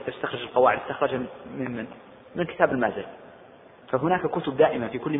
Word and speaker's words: المازري: المالكية عندهم كتاب هو كتاب المذهب لا قبله ولا بعده، ولذلك كل القواعد المستخرجة يرجعون لهذا الكتاب، وهو كتاب شرح التلقين للمازري، بعضهم ينطقه المازري وبعضهم ينطقه المازري، والأشهر بالفتح المازري.